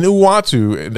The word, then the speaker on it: Uatu: I really do think, and Uatu, did I pronounce his name correct? – Actually, perfectly. I can't even pronounce it, but that definitely was right.